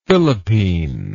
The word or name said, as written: Philippine